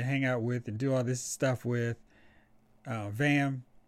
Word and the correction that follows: Zam: Now, why am I saying her name wrong? – Zam. Zam. Zam. – I was, I was like, Zam. I was like, wait, that's not right.